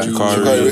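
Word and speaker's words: Sha'Carri: I was even going to say, it's been like a couple of days since it happened, but the whole thing with, is it Shikari, is that how you say it? – Yeah. – Shikari Richardson.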